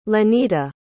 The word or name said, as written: lenita